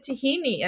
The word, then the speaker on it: tahini: And so the Greeks pronounce it tahini. And so Americans were first introduced to the ingredient as tahini.